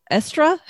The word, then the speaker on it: Eostre: It's spelled kind of oddly, so it's a little intimidating to look at. But it is spelled like the British use for estrogen. – Oh, okay. – It's the same way they have the spelling for estrogen, E-O-S-T-R-E.